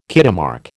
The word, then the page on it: Kitemark: American English